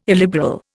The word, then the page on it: illiberal: Indian